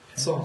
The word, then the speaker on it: Psalm: Psalm. – It's cute. – Song. How is that cute? How am I the bad guy here? – How do you spell that? – P-S-A-L-M. Psalm. Psalm.